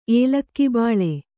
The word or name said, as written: ಏಲಕ್ಕಿಬಾಳೆ